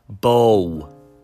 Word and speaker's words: Bow: Bow, pronounced like cow.